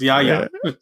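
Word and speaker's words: Yahya: You don't do Big Daddy Yaya that way, bro. It's Yaya. – Okay. – Big Daddy Yaya.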